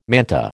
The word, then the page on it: manta: American English